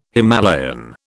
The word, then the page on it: Himalayan: British English